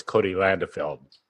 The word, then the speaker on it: Cody Landefeld: I'm going to call him Cody L., but I do know it's Cody Landefeld. – There you go. That was perfect pronunciation.